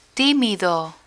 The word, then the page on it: timido: spanish